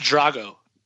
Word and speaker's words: Tragos: Tragos? Am I saying that right? – Sure. – It's pronounced Drago.